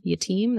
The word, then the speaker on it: Yatim: Suze is Suzyatim, Yatim.